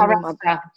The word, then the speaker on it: Maharashtra: It was actually in the middle of India in Maharashtra. I hope I'm saying that right and not offending Maharashtra.